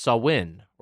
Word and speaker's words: Samhain: S-A-M-H-A-I-N. – That is correct. It's apparently pronounced Samhain or Samhain.